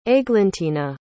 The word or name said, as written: aiglentina